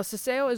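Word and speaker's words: ceceo: Now, ceseo is when they pronounce the letters S and Z, no matter where they are in the word. And C's, when they are followed by the letter E or I, otherwise known as a soft C, as th, like in the word thing and not then.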